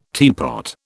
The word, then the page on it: teapot: British English